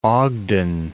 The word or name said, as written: Ogden